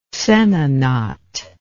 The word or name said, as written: sehna knot